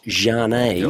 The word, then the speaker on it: Zhane: Z-H-A-N-E, pronounced Jane.